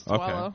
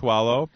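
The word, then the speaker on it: Tuaolo: Oh, I can never say his name, but the football player, Asera Tualo? – Yes, Tualo. – Okay, Tualo.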